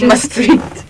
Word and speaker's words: Maastricht: So, in America, they call it Maastricht. It's not Maastricht. It's called Maastricht. But it's fine. – For just, starters. – Just Maastricht. – Maastricht, yeah. – Spell it. – M-A-A-S-T-R-I-C-H-T.